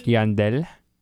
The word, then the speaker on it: Yandel: Would you even pronounce the Y? – Where? – Yandel. – Where are you looking? – Winston and Yandel.